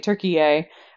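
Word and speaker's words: Türkiye: No, it's spelled differently. It has a number of accents in it. Yeah, it's pronounced differently, Turkiye, which is apparently how it would have been pronounced at some point in their history.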